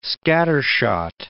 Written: scattershot

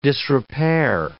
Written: disrepair

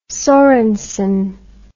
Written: sorensen

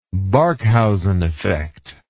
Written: Barkhausen effect

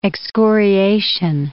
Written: excoriation

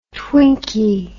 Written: twinky

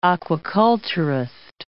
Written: aquaculturist

